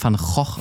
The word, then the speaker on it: Van Gogh: Spricht man den wirklich Van Gogh aus? – ja, die Holländer nennen den so.